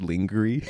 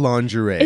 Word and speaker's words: lingerie: Like on The Bachelor when she said lingerie. – Yeah. – Bless her heart. It's lingerie.